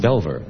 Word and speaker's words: Belvoir: There's another place in England, Belvoir. And we have a Belvoir here. It's pronounced Belver.